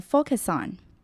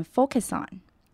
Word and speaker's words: focus on: Focus 这个字常常会有人把 C 的音念得太重而显得不自然，尤其在这里 Focus 后面紧接了一个 on，所以要注意要念成 focus on，字尾的 US 是短而急促的 us 的音，然后顺势跟 on 连起来就变成 focus on， focus on。